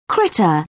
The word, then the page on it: critter: British English